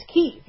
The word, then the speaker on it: Kyiv: It's Kyiv, named after Prince Kyiv. And it only took us until Putin invaded Ukraine for us to pronounce it correctly.